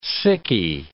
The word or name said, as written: sickie